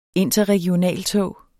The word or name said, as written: INTERREGIONALTOG